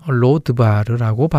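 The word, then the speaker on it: 로드바르라고 (לֹא דְבָר): 히브리어로는 로드바르라고 발음합니다.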